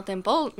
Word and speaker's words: Temple: Temple, non? – Sûrement, probablement. Définitivement Temple,